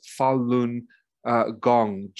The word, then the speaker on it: Falun Gong: So when Falun Gong particularly, and it's pronounced Falun Gong, G-O-N-G, Falun Gong, became the largest spiritual movement in China.